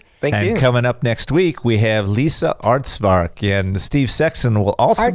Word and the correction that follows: Artsberger: Artsberger, sorry. Boy, did I butcher that one. Let's say that one more time. – Lisa Artsberger.